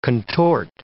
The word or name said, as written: contort